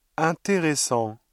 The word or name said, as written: intéressant